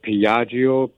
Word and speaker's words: Piaggio: And if I pronounce this right, Piaggio, P-I-A-G-G-I-O.